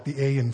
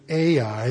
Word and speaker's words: Ai: A makes a sound like the A in far, ah. And then an I in this language, not in English, but in Hebrew, makes a sound like the I in sing or king.